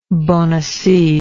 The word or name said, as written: bonaci